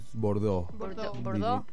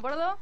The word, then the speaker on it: Bordeaux: Bordeaux. – ¿Como el color? – Bordeaux, sí. Claro. – Ah, bueno, Bordeaux.